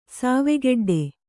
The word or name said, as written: ಸಾವೆಗೆಡ್ಡೆ